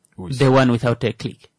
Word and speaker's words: Uis: Uis.